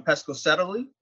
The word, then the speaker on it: Pescasseroli: For everyone who's out there, Dr. Giulia D'Arcangelo is now resident in Rome, uh, originally from Pescasseroli.